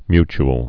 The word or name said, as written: mutule